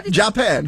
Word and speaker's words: Japan: Japan! – They put the accent on the last syllable.